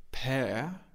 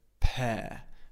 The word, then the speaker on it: pear: In British English, we have a non-rhotic accent. That means the R at the end of the word we don't pronounce. So we don't say pear, we say pear. You see, my mouth is completely open at the end. pair. Okay, we don't pronounce the R at the end. It's an open sound, pair.